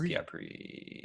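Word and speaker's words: Pyat Pree: We see, I think his name is Pyatt Pace. I don't know if I said that right, but he's the creepy charlatan. Yeah, Pyatt. What's his name? – I think it's Pyatt Pree.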